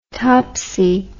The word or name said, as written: topsy